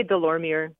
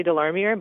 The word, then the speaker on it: Delormier: I say, well, if I'm saying it in English, I'll say Delormier. But if I say it in French, I'll say Delormier.